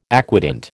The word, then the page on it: aquatint: American English